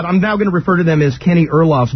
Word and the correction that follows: Aronoff: Bombastic Meatbats. – Aronoff. – Sorry. – Aronoff.